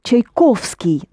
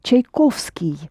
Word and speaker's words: Tchaikovsky: Tchaikovsky, not accented, that's the proper way to pronounce it. Tchaikovsky.